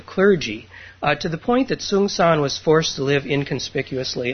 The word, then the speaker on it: Sengcan: And his name is spelled S-E-N-G-C-A-N, and it's pronounced Songsan.